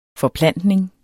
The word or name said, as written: forplantning